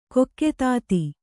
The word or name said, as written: ಕೊಕ್ಕೆತಾತಿ